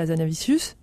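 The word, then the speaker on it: Hazanavicius: C'était en 2017 de Michel Azanavicius.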